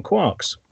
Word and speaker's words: quark: I mean, he laid down that you pronounce it quark, and then I think he post-fitted it to the literature. I think he just liked the sound of the word quark, and then found a literary reference where it appeared as quark. But I certainly quark is not it, quark it, is def- quark is definitely the pronunciation.